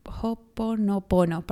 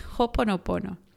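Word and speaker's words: hoponopono (Hoʻoponopono): Hoponono. hoponopono, perdón. Hoponopono. Se escribe H-O, y después tiene un apóstrofe, O-P-O-N-O-P-O-N-O. Hoponopono.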